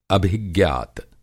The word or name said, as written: अभिज्ञात